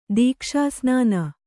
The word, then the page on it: ದೀಕ್ಷಾಸ್ನಾನ: dīkṣā snāna